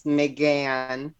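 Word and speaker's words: M'gann: And her her fake personification's name is Megan, and based off of this whole sitcom from the 80s, but she pronounces it McGann.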